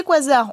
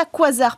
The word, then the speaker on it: quasar: on appelle ça quasar. Pourquoi Parce que c'est la contraction de quasi stellar en anglais, donc quasi stellaire, mais on a gardé euh, euh, la façon dans l'usage de le prononcer à l'anglaise.